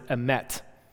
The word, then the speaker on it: emet: The Hebrew word for truth in verse 10, how you pronounce it, is emet.